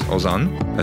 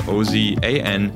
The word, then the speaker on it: Ozan: which is Ozan, that's spelled O Z A N, to 345 345.